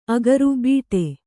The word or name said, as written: ಅಗರು ಬೀಟೆ